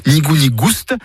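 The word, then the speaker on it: ni goût ni gouste: Nigou nigouste, avec prononciation du S originel que l'on retrouve dans le provençal goustus, goûteux, et qui a été remplacé depuis par l'accent circonflexe dans notre orthographe moderne.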